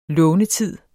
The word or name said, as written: lånetid